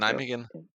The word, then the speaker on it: Nijmegen: altså kan du udtale den klub der? Hollandsk klub? – Øh, var det ikke øh, Nijmegen? Er det ikke Nijmegen, NEC Nijmegen? Jo.